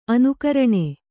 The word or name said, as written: ಅನುಕರಣ